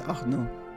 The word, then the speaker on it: Arnaud: I think that's Arnaud. – Yeah, because you don't pronounce the D, do you? – No.